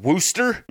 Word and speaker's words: Worcester: Like Worcester, W-O-R-C-E-S-T-E-R. But yeah. I've heard people up there call it Wooster.